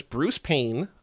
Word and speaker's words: Payne: I did not recognize the main villain, but his name is Bruce Payne, spelled like Bruce Wayne, but with a P. – Yes. – His character name is Charles Raine, R-A-N-E,